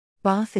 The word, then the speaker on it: Bath: For example, Bath is pronounced with the short /a/ sound rather than the longer // of received pronunciation.